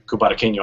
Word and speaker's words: Cubarqueño: And they'll hopefully teach Logan how to say Cubarqueño. I feel like I'm saying it right. Every time Juan says it, he says it so fast that I can't. – You nailed it.